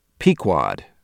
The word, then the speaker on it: Pequod: Until somebody, I think it might have been Terrence, figured out that that's Pequod. The boat in Moby Dick, the ship, I should say, in Moby Dick is the Pequod.